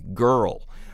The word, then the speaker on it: Grrrl: Riot Grrrl, girl spelled with three R's, no I, girl.